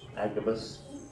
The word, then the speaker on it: Agabus: Agabus.